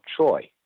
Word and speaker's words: Choi: And he had studied Korean calligraphy with Don Il, who was the Taekyon instructor for General Che, or some people pronounce it General Choi, Choi Hong-hee.